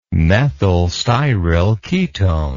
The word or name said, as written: methyl styryl ketone